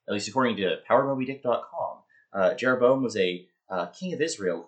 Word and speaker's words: Jeroboam: Jeroboam is how I would pronounce it.